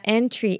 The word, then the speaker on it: entry: entry entry这个字呢注意try 不是发try哦是tree的一个长母音 entry